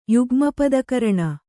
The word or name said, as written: ಯುಗ್ಮಪದಕರಣ